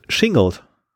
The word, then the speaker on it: Shingled: Shingled. Schingelt? Ja, S-H-I-N-G-L-E-D.